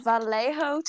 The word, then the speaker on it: Vallejo: It's the Vallejo Times. It's spelled with a J, but I know how to pronounce it.